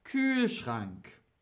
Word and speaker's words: Kühlschrank: Wie wird's ausgesprochen? Kühlschrank. Kühlschrank. Also in meinem hm ist keine Milch mehr. In meinem Kühlschrank mit Umlaut, mit Umlaut. K-Ü. Ähm, H-L-S-C-H-R-A-N-K.